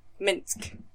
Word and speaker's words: Minsc: How is it spelled? – M-I-N-S-C. Minsk.